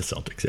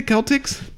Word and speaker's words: Celtics: They're the Celtics, yes. – Well, shouldn't they be the Celtics? – No, it's modern now. It's a soft C.